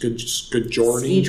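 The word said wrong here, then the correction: skijoring: Skidjoring. – Skidjoring.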